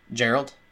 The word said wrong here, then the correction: Geralt: Geralt. – Geralt. – Hard G.